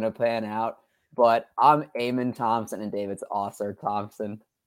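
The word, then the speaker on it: Amen: It's Eamon Thompson. So Eamon. – Get it right before we start our fantasy show and we're drafting him.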